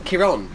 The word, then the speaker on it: Kieron: Kieran? Kieron. He's from England. I imagine that's how it's pronounced. – Not Karen. – No. K-I-E-R-O-N.